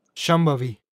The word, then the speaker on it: Shambhavi: ¿Puedes pronunciar Shambhavi? Shambhavi. Sí, estupendo.